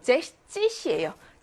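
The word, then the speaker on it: sechzig: 60은 또 예외입니다. 어떻게 예외야? 16이 제시첸인 것처럼 60도 제시찌시예요. 잭스 아니고 S가 사라졌고요. 17이 집첸인 듯이 70도 집찌시입니다.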